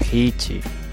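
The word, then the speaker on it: Heechee: Esta saga gira en torno a unos misteriosos extraterrestres llamados los Hichi, escritos E-H, aunque su pronunciación en inglés sería Hichi.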